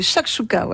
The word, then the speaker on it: shakshuka: Oui, c'est comme ça que ça se prononce. Oui, shakshuka, voilà.